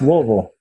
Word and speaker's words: Louisville: You got to swallow the middle syllables. Louisville.